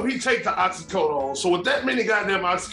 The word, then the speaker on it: oxycodone: According to TMZ, officers claim that they found 31 oxycodone pill.